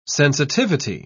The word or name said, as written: sensitivity